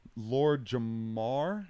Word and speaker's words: Lord Jamar: So Lord Jamar, did I say it right? – Mm-hmm.